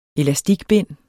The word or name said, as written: elastikbind